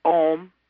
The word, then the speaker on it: Ogham: It was written in Ogham, O-G-H-A-M, which some pronounce Om, but we have said Ogham and pronounced the hard G.